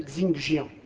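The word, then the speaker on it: Xingjian: Xingjiang, prononcez donc à la française Xingjiang.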